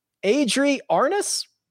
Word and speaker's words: Adri Arnaus: Adri Arnaus, I believe is how he pronounces it.